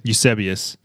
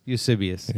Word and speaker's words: Eusebius: Eusebius. – Eusebius.